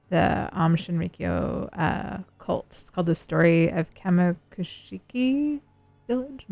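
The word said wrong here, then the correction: Kamikuishiki: Am I saying that right? – Yeah, Kami Kuishiki Village.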